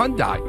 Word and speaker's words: Hyundai: How do you pronounce it? Hyundai. Hyundai ESPN LA. – Hyundai.